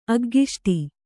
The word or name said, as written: ಅಗ್ಗಿಷ್ಟಿ